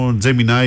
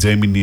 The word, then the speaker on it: Gemini: Μετά και το Gemini ή Gemini, αναλόγως πως το προφέρει.